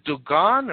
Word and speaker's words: Dogon: You've done a tremendous amount of research and study on the, is it the Dogon or Dagon people? How do I pronounce? – Dogon. – Okay.